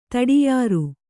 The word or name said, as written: ತಡಿಯಾರು